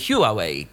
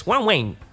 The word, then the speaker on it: Huawei: Ja zawsze myślałem, że to się wymawia Huawei, tak z angielska, a to się okazuje, że to jest Huawei, tak?